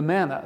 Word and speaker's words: manna: So on the one hand, you have what must have made a pretty good stir fry compared with the manna. The word manna, by the way, is literally the Hebrew word for what is it? Manna is how you say it in Hebrew.